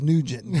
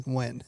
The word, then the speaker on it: Nguyen: There's a lot of letters, and it's if you try to pronounce it English, it says Nugent, but when you pronounce it in Vietnamese, it's Nguyen.